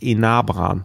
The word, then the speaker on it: Enabran: Ja, und ich glaube, jetzt muss ich mal sagen, ich glaube, ich habe äh, Enabrantein die ganze Zeit falsch ausgesprochen. – Hatte ich heute noch irgendwo gelesen. Ich glaube, in so einem Originaldrehbuch. Und dann haben sie oben irgendwie abgedruckt, wie man die Namen ausspricht. Enabran äh, sollte der heißen.